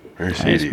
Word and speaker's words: Mercedes: Every single E, all 3 E's in Mercedes are pronounced different. Er-A-E. Mercedes.